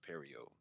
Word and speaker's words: Pierrot: That it looks good in motion, but whenever you pause the joint, but I think Pierrot, and also it's pronounced Pierrot. Sorry, y'all.